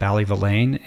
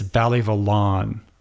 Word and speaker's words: Ballyvillane: I keep calling it Ballyvillane, and all the Irish people keep tweeting me and telling me it's Ballyvillan. Or I'm pretty sure that's how you pronounce it, Ballyvillan.